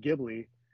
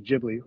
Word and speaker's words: Ghibli: I was saying Ghibli, and people are, but I guess the correct pronunciation is Ghibli with the soft G.